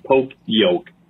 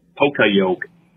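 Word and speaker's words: poka yoke (ポカヨケ): which is not fucking poke yoke. It's poka yoke. Like Polish poka for some reason.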